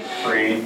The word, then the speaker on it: Friel: Todd Friel? Is that how you pronounce his name? – Free.